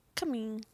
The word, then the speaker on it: Cumming: It's C-U-M-M-I-N-G. – How would you pronounce that? – Say it one more time. – C-U-M-M-I-N-G. – Cumming. – Yep, that's how I pronounce it too.